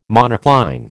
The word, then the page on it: monocline: American English